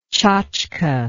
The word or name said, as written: chachka